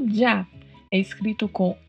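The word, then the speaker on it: ya: E a pronúncia desta Y junto com a letra A é uma pronúncia semelhante a um J. É como se fosse um D e um J juntos. Já.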